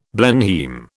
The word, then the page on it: Blenheim: British English